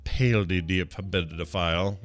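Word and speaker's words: paedophile: His brother Andrew's crisis over his friendship and with pedophile, or as they say in Britain, pedophile. Look how they spell it. – Pedophile. – Yeah, they say pedo, but they spell it P-A-E.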